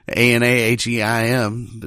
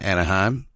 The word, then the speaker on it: Anaheim: Anaheim. It's not Anaheim. It's Anaheim.